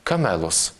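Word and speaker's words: камелос (κάμηλος): По-гречески слово «верблюд» произносится как «камелос».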